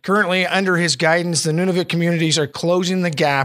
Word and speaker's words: Nunavut: Um, I guess kind of the key to anything in the Inuktitut language uh, is there are three core vowel sounds. So anything with a U is an U sound, anything with an A is an A sound, anything with an I is an E sound. So it's Nunavut, it's uh, Inuktitut is the language.